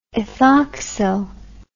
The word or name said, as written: ethoxyl